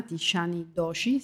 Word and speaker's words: Tishani Doshi: Eh, lei è sposato con una donna indiana, Tishani Doshi, si pronuncia così? – Sì, sì, la pronuncia giusta.